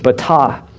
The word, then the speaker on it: batah: I'm not a Hebrew scholar, but apparently that's the way you pronounce it, batah.